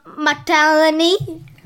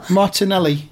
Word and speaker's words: Martinelli: Arsenal signed Gabriel Martellini. – Right. Who? Is he the Brazilian lad? – Yeah. – How do you pronounce that really, though? – No. – Martinelli.